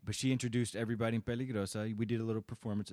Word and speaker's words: Peligrosa: Peligrosa. – And I say it correctly by rolling the R's. Otherwise, you're not legit. You can't get in if you don't pronounce it correct.